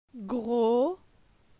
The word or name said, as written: Gro